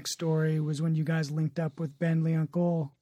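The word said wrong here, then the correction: Ben L'Oncle Soul: Ben Lunkelsoul.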